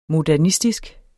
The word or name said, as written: modernistisk